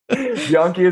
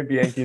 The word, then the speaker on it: Bianchi: Bianchi is the Italian way. Bianchi is the Canadian way.